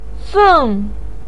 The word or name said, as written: feng4